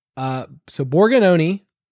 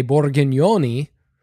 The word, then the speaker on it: Borgagnoni: So Borgagnoni is how you say it. In Italian, we would say Borgagnoni, which is really fun to say. – Fancy. – Borgagnoni.